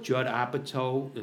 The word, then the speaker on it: Apatow: Judd Apatow, am I pronouncing it correctly? – Yeah.